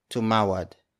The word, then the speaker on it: tumawad: And then the third word is tumawad. It's spelled as T-U-M-A-W-A-D.